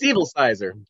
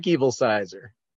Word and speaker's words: Evilsizor: It's pronounced just like it sounds. – Yeah, I know. How is that? – It's Evilsizer. Derek Evilsizer.